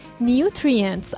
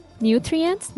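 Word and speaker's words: nutrients: خواهش میکنم کلمه که امروز آوردم کلمه نیوتریانس انگلیسی هستش نیوترینس به معنای مواد مغذی هست در فارسی نیوترینتس البته این کلمه مفردم که ماده مغذی میشه هستش اسپل میکنم که راحتتر تلفظش براتون باشه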